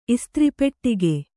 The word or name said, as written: ಇಸ್ತ್ರಿಪೆಟ್ಟಿಗೆ